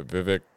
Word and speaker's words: Vivek: Yeah, Vivek.